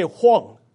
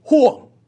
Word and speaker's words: Rouen: You may say Huang, but in French it's Huang. I'm being corrected. R-O-U-E-N. Huang.